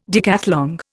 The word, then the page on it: decathlon: Indian